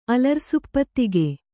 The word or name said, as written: ಅಲರ್ಸುಪ್ಪತ್ತಿಗೆ